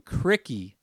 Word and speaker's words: Krikke: Number five, Ben Krikke. Yes, that is how you say his last name. Krikke is transferring from Valparaiso to Iowa.